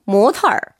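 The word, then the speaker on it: 모털 (模特儿): 발음도 굉장히 비슷해요. 2번 모털. – 털이라고 그러네요. – 얼화시켜서.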